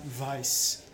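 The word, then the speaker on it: Weisse: Source Brewing, Follow Your Passion, Passion Fruit Berliner Weiss. – You said it right this time. – Yeah, I always used to say Weiss when I didn't know what I was doing.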